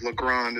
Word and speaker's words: Legrand: which is a brand of Legrand, is the right way to say that.